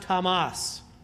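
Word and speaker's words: Hamas (חמס): Thankful to Jeff Hathaway, who pointed out to me that the Hebrew word for violence is actually pronounced Hamas.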